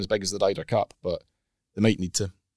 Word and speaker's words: Ryder: Forget the spelling. It's pronounced Ryder Cup. Ryder.